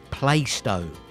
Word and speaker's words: Plaistow: It's pronounced Plaistow.